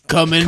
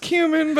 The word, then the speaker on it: cumin: Well, coming. – Coming, for sure. – We definitely used a bunch of coming on these cucks. – It's pronounced cumin, but you get the joke.